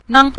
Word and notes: nang: nang5

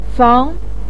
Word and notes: fang2: pinyin

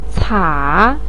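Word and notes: ca3: tone 3

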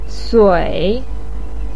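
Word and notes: sui: tone 3

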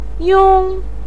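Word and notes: yong: tone 1

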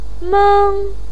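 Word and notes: meng1: pinyin; tone 1

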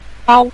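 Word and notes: bao: tone 5

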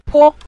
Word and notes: po: po5

